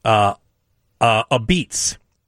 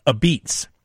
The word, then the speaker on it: apizza: So now, if you ever travel to New Haven, Connecticut, you might hear the locals order a beets. And it's spelled A-P-I-Z-Z-A, like a pizza. But it's pronounced a beets.